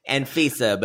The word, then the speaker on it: Anfisa: George and Antifa, otherwise known as Anfisa, but someone said Antifa earlier. – I did earlier. – Say it wrong.